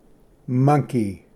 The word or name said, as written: ᎠᏓᎴᏍᎩᎢᏍᎩ